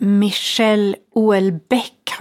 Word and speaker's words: Michel Houellebecq: Mikael Hollebeck, uttalar man hans namn så? – Du, det är Michel Houellebecq har jag hört.